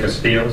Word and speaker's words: Castillo: Gary Higgins, H-I-G-G-I-N-S, classification and parole representative. – Laura Flemate, F-L-E-M-A-T-E, office assistant. – Victor J. Castillo, C-A-S-T-I-L-L-O, assistant CMPR. – Officer A. Los Santos, D-E-L-O-S-S-A-N-T-O-S, correction officer, CSP Corbin.